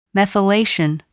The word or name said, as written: methylation